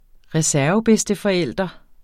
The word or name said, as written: reservebedsteforælder